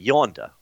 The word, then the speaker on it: Yanda: Yonda. What did I say, Yanda? No, it's spelled that way, but it's Yonda, I mean.